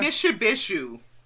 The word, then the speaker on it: Mishipeshu: Mishipeshu.